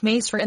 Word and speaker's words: Misr: The Hebrew Mazur is preserved in the Arabic Misr, pronounced Masr in the vulgar dialect of Egypt.